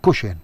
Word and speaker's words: kuxin: U fejn hemm per eżempju kuxin. L-aċċent mhux hi qed iġorru, imma qed iġorru l-ewwel sillaba. Alright? Kuxin.